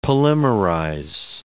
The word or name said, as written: polymerize